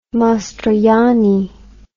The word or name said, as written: Mastroianni